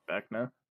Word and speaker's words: Vecna: Be Wild, Vecna from Stranger Things. Did I say that right? – Yeah, Vecna.